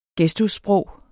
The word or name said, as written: gestussprog